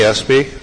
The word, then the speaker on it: Espey: My name is Tony Espy. I live at 511 Fruit Farm Road, Hollister. Last name is spelled E-S-P-E-Y.